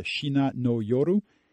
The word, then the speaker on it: Shina no Yoru: Carol, we got a song here to play, and correct me if I pronounce it wrongly, but a Shina no Yoru.